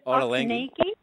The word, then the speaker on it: Ottolenghi: Ottolenghi.